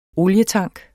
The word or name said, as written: olietank